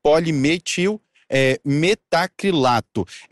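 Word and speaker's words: polimetilmetacrilato: Ela foi lá para fazer um procedimento de remodelação corporal e com aplicação de polimetimetacrilato. Desculpe a pronúncia, porque é uma palavra um pouco mais complicada, mas a abreviação é bem popular. O PMMA, vou repetir, polimetil metacrilato.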